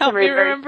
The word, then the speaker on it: Timaree: Well, my name's Timaree, very weird.